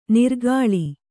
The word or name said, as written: ನಿರ್ಗಾಳಿ